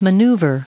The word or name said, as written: maneuver